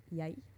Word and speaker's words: jeg: or maybe like first a standardized word is jeg. And the pronunciation can vary a little bit, but it's spelled jeg. So it's like J-E-G.